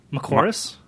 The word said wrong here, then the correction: McMorris: McMorris. – Mark McMorris. – That's what I said. Oh, okay. – Yeah. Dropping an M there.